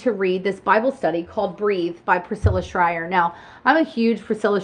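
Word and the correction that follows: Shirer: I'm pronouncing her name wrong, Shire.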